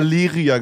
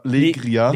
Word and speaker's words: Legria: Und das Ganze wurde mit einer Leria gefilmt. Die filmen 2022 noch mit einer Leria. Legria.